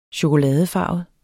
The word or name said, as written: chokoladefarvet